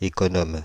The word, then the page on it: économe: France; [e.kɔ.nɔm]; Paris